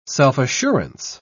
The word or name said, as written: self-assurance